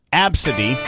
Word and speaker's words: ABCDE: Some of the names that people can't believe a parent actually used on their own kid, ABCDE, which is pronounced Absidy.